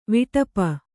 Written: ವಿಟಪ

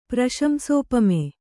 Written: ಪ್ರಶಂಸೋಪಮೆ

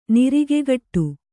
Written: ನಿರಿಗೆಗಟ್ಟು

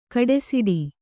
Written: ಗುಡುಸೆಟ್ಟಿ

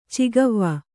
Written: ಚೌಗಾವೆ

